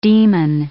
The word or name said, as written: demon